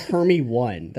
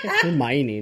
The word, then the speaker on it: Hermione: Dude, that shit spelled Hermie 1. That's not even a real name. Hermione.